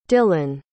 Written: dillen

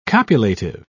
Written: copulative

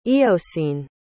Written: eocene

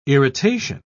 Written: irritation

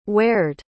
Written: where'd

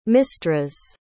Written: Mystras

